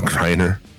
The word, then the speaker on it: Griner: Brittany Griner. – Griner? – I don't know how to say her name. No, Griner.